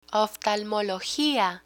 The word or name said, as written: oftalmologia